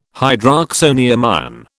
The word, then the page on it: hydroxonium ion: British English